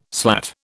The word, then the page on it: slat: British English